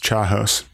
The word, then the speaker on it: chaos: It's pronounced chaos. – sorry, chaos.